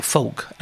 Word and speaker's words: folk: She's a folk singer. – Folk. – Folk. Folk. Yeah. – Folk. – I never know how to pronounce that word. – Folk. – Folk. – Yeah. Folk singer. – F-O-L-K.